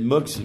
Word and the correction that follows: Moix: Mox, Moix, j'ai jamais à prononcer son nom. – voilà. Moix on, dit que on dit que c'était une calamité cette décision.